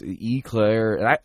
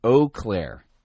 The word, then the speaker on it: Eau Claire: Yeah, I remember the other day, Kyle wrote me and I couldn't pronounce the name of his town. I was Eclair. I thought it was Eclair. Now, apparently I was severely mispronouncing this. It isn't Eclair.